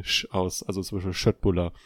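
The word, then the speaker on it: Köttbullar: Die beliebten, die beliebten Fleischbällchen aus Fleischbällchen Ikea. von Ikea, man, ja. Spricht man Schöttbuller aus.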